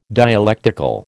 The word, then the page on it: dialectical: American English